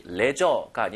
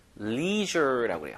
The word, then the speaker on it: leisure: 자, 발음이 레저가 아니라 leisure라고 그래요. 제 발음 보세요. leisure. leisure, leisure가 좀 어렵죠, 한국 사람한테.